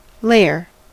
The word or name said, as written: layer